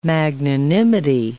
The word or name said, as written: magnanimity